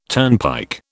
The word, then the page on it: turnpike: British English